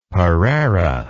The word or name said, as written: pareira